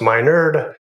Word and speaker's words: Minard: I'm your host, Dr. Matt Minard, pronounced Minard, and I am honored to be your host.